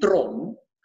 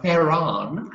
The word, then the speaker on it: Theron: I mean, in South Africa, people say Charlize Theron, because that's how we pronounce Theron. – Theron.